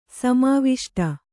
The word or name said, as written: ಸಮಾವಿಷ್ಟ